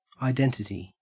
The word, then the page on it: identity: Australian English